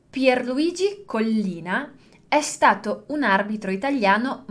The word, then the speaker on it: Collina: Vamos pronunciar primeiro um L e depois o outro. Collina. Collina. Então, a gente tem a sílaba col, e depois a sílaba li. Então, a gente tem esse primeiro L, pronuncia ele sozinho, e depois pronuncia o outro também.